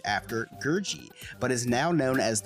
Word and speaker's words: Gurgi: It's pronounced Gurgi as a heads up.